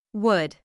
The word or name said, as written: would